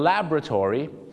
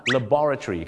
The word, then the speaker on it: laboratory: การจะออกเสียงว่า laboratory the laboratory แต่ชาวอังกฤษจะออกเสียงว่า laboratory ครับ laboratory laboratory อเมริกา laboratory ก็คือชาวอังกฤษ